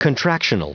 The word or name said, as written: contractional